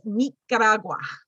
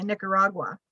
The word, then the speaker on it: Nicaragua: say it in the proper way from her native country, and the word is Nicaragua. I just love the way it came off her lips, the way she pronounced. I mean, anybody else would just say Nicaragua, which sounds pretty boring, but when you hear it the way she said it, I was like, that is so gorgeous.